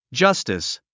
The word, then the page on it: justice: dʒʌ’stis; ジャスティス